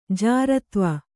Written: ಜಾರತ್ವ

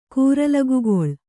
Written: ಕೂರಲಗುಗೊಳ್

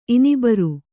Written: ಇನಿಬರು

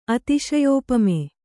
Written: ಅತಿಶಯೋಪಮೆ

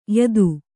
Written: ಯುತಿ

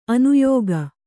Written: ಅನ್ಯಕ

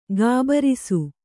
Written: ಘಾಬರಿಸು

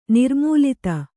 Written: ನಿರ್ಮೂಲಿತ

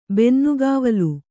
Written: ಬೆನ್ನುಗಾವಲು

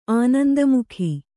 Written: ಆನಂದಮುಖಿ

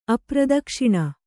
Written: ಅಪ್ರದಕ್ಷಿಣ

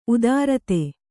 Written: ಉತ್ತರಾಧಿ

